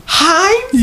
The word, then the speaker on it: Heinz: The 57 on the ketchup bottle, and it's I don't know how to pronounce it. It's H-E-I-N-Z. The Henzies ketchup. – Heinz.